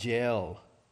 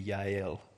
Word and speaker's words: Jael: But Sisera fled away on foot to the tent of Jael. Again, I'm giving you southern pronunciation. That would be Yael in Hebrew.